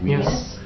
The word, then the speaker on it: Gwines: G-W-I-N-E-S. – That's nice. Pronounce it. – Guinness.